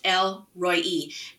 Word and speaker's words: El Roi: Two words, El Roi, R-O-I. It's pronounced El Roi, and it means it's the God who sees.